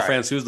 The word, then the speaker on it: Francouz: Pavel Francouz, the goalie? – Yeah, I tried.